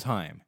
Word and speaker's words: Haim: And my first thought is, oh, David Letterman pronounced their name wrong. But then she plays another video and someone else is like, here's Haim. So the name's Haim, first of all, okay.